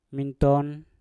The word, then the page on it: minton: Mpur